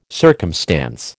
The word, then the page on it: circumstance: American English